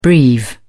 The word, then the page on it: breve: British English